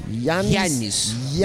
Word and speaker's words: Yannis: Yannis. – There's like an I in there. Yannis. Yannis Pappas. Pappas, the P almost sounds like a B.